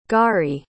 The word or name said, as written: gari